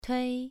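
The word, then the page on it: tui: tone 2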